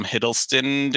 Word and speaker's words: Hiddleston: mostly because of the actor is Tom Hiddleston.